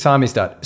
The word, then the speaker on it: samizdat: Samizdat, is that how you say it? Yeah, I think so. – Samizdat. Yeah. – It's Samizdat. So as a last thing,